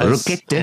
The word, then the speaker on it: Rochette: Rochette, ja.